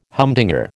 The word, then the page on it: humdinger: American English